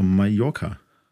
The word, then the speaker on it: Mallorca: Und da sage ich, das heißt ja gar nicht so, das heißt doch Mallorca. The, ja. sage ich, ne, weil L bei denen wie J ausgesprochen wird.